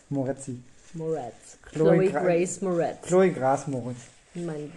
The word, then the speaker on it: Grace: Äh, wer tatsächlich relativ frisch in dem Film eingeführt wurde, war Chloe Gras Moretz. Wie, wie spricht man die richtig aus? – Also Gras bestimmt nicht. – Chloe, Chloe Grass. – Chloe Grace, Grace Moretz, würde ich sagen.